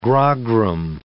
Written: grogram

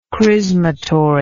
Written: chrismatory

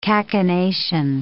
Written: cachinnation

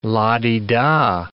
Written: la-di-da

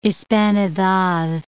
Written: hispanidad

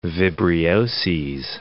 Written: vibrioses

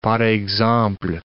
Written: par exemple

